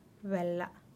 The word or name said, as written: belaa